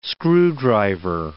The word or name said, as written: screwdriver